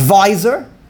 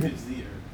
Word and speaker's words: vizier: his visor. There's a visor, there's a king, and there's a visor, and... – Vizier.